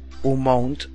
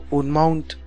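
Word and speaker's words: umount: Otro tipo me dice, cuando pronuncias un mount, yo oigo un mount. Si la gente pone un mount, no le va a reconocer ese comando. No sé si es el principio de la M que hace que la U se oiga como O, si es que lo pronuncias mal por desconocimiento o despiste.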